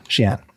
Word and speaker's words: Xi'an: X I A N, Xi'an.